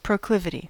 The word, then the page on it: proclivity: American English